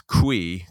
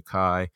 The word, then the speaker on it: Cui: And I always thought it was Cui because it's spelled C-U-I. But then I decided to search her up. And she wrote a book. And so and she's on video. She says, "Hi, I'm Linda Kai."